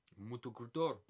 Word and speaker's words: Motocultor: Motocultor.